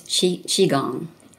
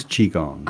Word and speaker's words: Qigong: I'm just curious, did you have any kind of health issues yourself that got you to, is it properly pronounced Qigong? – It's Qigong. – It is Qigong.